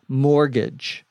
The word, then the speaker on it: mortgage: In the story, I say that my problem is I can't afford a high mortgage. A mortgage, M-O-R-T-G-A-G-E, the T is silent, is a loan that you use to buy a house.